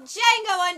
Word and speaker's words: Django: Jackson, Kerry Washington, and it's directed by Quentin Tarantino, and it's called Django Unchained, The D is Silent.